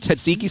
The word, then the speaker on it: tzatziki: Am I pronouncing it right? Tzatziki sauce? How should I be pronouncing it? – You're actually saying it correctly. Tzatziki.